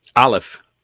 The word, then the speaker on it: Aleph: I know what the one is, but now the first one you got up there is, how do you say that? A leaf? – Aleph. – Aleph. Okay.